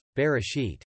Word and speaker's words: Bereshit: The Hebrew title for Genesis is Bereshit, pronounced Bereshit. Bereshit simply means, in the beginning.